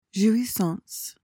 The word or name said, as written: jouissance